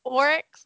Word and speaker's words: Oryx: You say it like Clorox. – I do. I can say Oryx when I think about it.